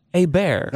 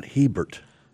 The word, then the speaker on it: Hebert: It's pronounced Hebert. – Not Hebert. – But it's spelled Hebert.